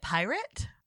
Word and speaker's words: Pyrt: He's out here with a new scheme and he is calling it Pirate. Spelt P-Y-R-T. – Okay. – Pronounced pirate.